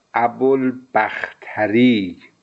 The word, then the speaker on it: ابوالبختری: این تلفظ ابوالبختری تلفظ صحیح نیست. در همون اواخر جلسه هم به ذهنم رسید که باید تلفظ صحیح چیز دیگری باشه و وقتی مراجعه کردم به سندهای معتبر ابوالبختری اطلاق صحیح تلفظ این راوی است.